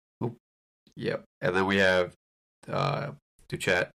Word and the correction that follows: Doucette: Doucette, I believe, is the official pronunciation, at least from the highlights I've watched of his many, many, many, many goals.